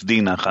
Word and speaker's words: Dinach: So, in May of 1921, our Swiss teacher, Paul Amadeus Dinach, I'm using the German pronunciation, he slept in a rare state of consciousness, okay, which was a lethargic sleep for almost a year.